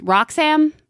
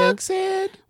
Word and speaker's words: Wroxham: So if you drive a little bit out of Norwich, or I guess like a subdivision of Norwich is Roxham. It's spelled with a W. – Roxham. – Well, it's WROXHAM.